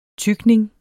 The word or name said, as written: tykning